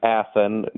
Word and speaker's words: Athan: Yes, the name Athan, not spelled like Athens, Greece, but Athan, A-T-H-A-N, is short for Athanasios, which is Greek, and he is the ancient Greek bishop from the era of Christ.